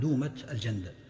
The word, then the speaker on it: دومة الجندل: أيضا في هذه السنة وقعت غزوة تسمى دومة الجندل، تنطق بدومة بفتح الدال وتنطق بضم الدال دومة الجندل، وهذه تبعد عن المدينة 15 يوما أو ليلة شمالا، وتبعد عن دمشق 5 ليالي، وهي في شمال جزيرة العرب.